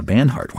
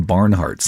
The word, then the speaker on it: Banhart: Banhart. Why do I always say that wrong? I always want to call him Barnhart. Sounds like a like a jacket that you'd wear in the wintertime.